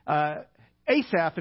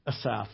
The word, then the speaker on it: Asaph: The guy who wrote this particular psalm is a guy named Asaph, if you're from Arkansas, okay? But if you listen to the pronunciation guide in Logos Bible software, it's Asaph, okay? So if I call him Asaph, that's because I'm from Arkansas.